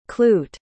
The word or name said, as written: cloot